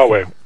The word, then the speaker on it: Huawei: First of all, how do you pronounce the damn thing? Huawei? Is a, this thing going on between. – Yeah, Huawei.